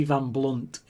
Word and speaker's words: Blundht: Eddie Van Blunt is constantly telling people that it's got a H in his name. The H is silent.